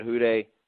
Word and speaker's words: Hude: It's your boy, Ed Hude, spelled with a U, not an O.